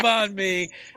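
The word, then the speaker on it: bánh mì: Yeah, and next time I get the banh mi. And, am I saying that right again? – Yeah, banh mi, that's right.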